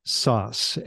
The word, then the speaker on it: Szasz: So it's Elijah Sauce, and that is just like pizza sauce, but it is spelled SZ, ASZ, and that's from Hungary, where they like to just throw random consonants in the middle of names to mess people up.